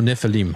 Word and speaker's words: Nephilim: How do you pronounce it? Nephilim, is that how you say it? – Yeah, if you look at it, Nephilim, I-M, Seraphim. – Seraphim. – You know, it's everybody pronounces it a little bit different. A lot of people use the soft version of the I. So, either is acceptable.